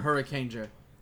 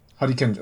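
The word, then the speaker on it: Hurricaneger: I mean, even the friggin' Sentai Senpai, friggin' from, what, Kakurenger and also Harikenger. – Hurricanger. – Harikenger, whatever. I'm going to say it like a Japanese guy because I...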